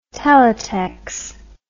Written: teletex

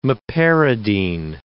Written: meperidine

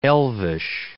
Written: elvish

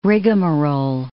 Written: rigmarole

